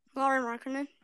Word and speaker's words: Lauri Markkanen: Lauri Markkanen. – Lauri Markkanen.